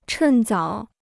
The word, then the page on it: 趁早: chèn zǎo